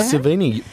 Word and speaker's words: Sevigny: Yeah, starring Chloe Sevigny. How do you say that? Is that right? – Sounds right. Sevigny, bang on the money.